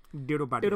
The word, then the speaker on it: Tirupati: Tirupati. – Tirupati. Have I said that right? Well done, good.